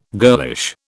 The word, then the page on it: girlish: British English